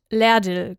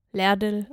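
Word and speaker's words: Lardil: Auf einer Insel an der Küste Australiens, auf Morrington Island, wird Lerdil gesprochen. Ladil geschrieben, Lerdil ausgesprochen.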